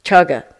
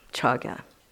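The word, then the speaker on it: cāga: Is that how you say it, kaja? Huh? Chaga. Oh, chaga, chaga. Good, it's good that we have a Pali person here, chaga.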